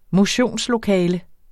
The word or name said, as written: motionslokale